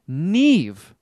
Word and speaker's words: Niamh: I'll go Neve. – Neve. All right. Boy, your pronunciation of N-I-A-M-H.